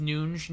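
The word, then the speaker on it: Núñez: We'll save that for another time, but let's get back to Darwin Nunes. – Is that how you pronounce it? Is it like the Bruno Fernandes? – Yeah.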